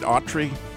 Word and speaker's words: Autry: Am I saying that right, Autry? – You got it.